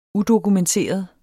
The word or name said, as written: udokumenteret